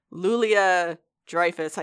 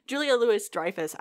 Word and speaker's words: Julia Louis-Dreyfus: I always get like Lulia Dreyfus. I get it mixed up in my brain. Lulia, you know, Lulia. No, Julia Louis-Dreyfus.